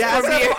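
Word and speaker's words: Fovere: Y'all made a fool of yourself. It's Forvir. – I was like, it's not even close to Forever.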